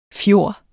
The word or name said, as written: fjord